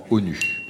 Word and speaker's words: ONU: Que signifie l'abréviation, le sigle en français ONU, sans erreur ONU ou ONU C'est un sigle, c'est aussi un acronyme puisque on le prononce souvent, on dit souvent ONU.